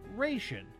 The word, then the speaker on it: ration: We pronounced it ration. In case you haven't noticed, the A in the first syllable now sounds more like one in admirable.